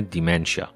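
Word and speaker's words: dementia: جدای از بحث افسردگی، ما در علم اعصاب، مغز و اعصاب، نورولوژی یه بیماری دیگه داریم به نام دیمنشا یا دمانس با تلفظ فرانسه، یعنی زوال عقل، چیزی که آلزایمر در دل اون جا میگیره.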